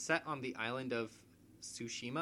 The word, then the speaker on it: Tsushima: It's Ghost of Tsushima is, I believe, the way the title Tsushima. – Is. Tsushima.